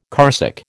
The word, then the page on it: carsick: American English